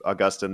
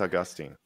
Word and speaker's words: Augustine: I know that highly educated people prefer Augustine, but I say Augustine because in Latin it would have been pronounced Augustinus. So that sounds like Augustine, right?